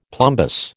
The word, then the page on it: plumbous: American English